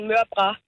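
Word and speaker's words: mørbrad: Jamen altså, på slagteriskolen, der udtaler man den mørbra.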